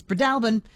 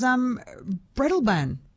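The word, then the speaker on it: Breadalbane: Katrina in Roslyn says Fiona's correct. It's Bredalbin. Louise in Nowra says, Jess, I've only ever heard it as Bredalban. I'm probably mispronouncing the mispronunciation.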